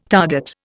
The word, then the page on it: target: Indian